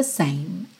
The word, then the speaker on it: same: Repara como esse som termina aqui, same. Tá? Esse E final não é pronunciado e você realmente ignora completamente. Same.